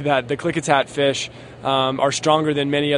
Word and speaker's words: Klickitat: Klickitat River.